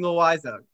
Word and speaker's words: Loaiza: Jonathan Loaiza."